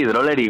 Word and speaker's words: Drollery: Al otro lado del teléfono, de Drolery. Creo que lo digo bien, ¿no? – Sí, sí, Drolery, como si solo tuviera una L.